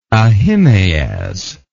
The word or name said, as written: Ahimaaz